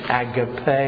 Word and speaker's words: agape: If you don't know Greek, and I don't, but you pronounce it agape, A-G-A-P-E, which is God's love, the pure love from God.